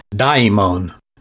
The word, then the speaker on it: daimon: And that Greek word is daimon, and it's spelled D-A-I-M-O-N.